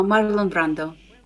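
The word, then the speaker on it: Marlon: А, кстати, э, как правильно? Как ты произносишь, Марлон или Марлон? – А, Марлон Брандо. – Марлон Брандо. А это, это ты считаешь, это правильно так, да? – Да, это правильно. Ну, э, э, акцент на Марлон.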